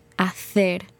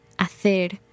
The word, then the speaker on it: hacer: Instead of hacer, like they might say in Mexico or Colombia, she said hacer, th. And that's called ceceo, where instead of pronouncing the letter C as s, they say th, kind of with a lisp.